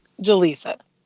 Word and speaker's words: Jalessa: How do you say your name? Jalisa or Jalisa? – Jalisa.